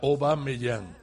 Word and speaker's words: Aubameyang: Aubameyang se pronuncia Aubameyang, porque Au, en Gabón, francés. – En Gabón, el idioma oficial de Gabón es francés. – ¿Sí? – Y au en francés es un diptongo que se pronuncia o, como chateau es chateau, como eau es o, agua. Y, así que yo creo además que es incluso más fácil, Aubameyang.